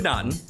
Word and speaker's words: Naughton: It's 1981's American Werewolf in London, starring David Nonington, Griffin Dunne, Jenny Ogator? – I'm pretty sure you've pronounced every person's name wrong. – I know. David Naughton, Griffin Dunne.